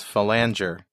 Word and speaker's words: phalanger: The pronunciation is phalanger, if you're talking about the possum, which is the only other definition besides the compiler.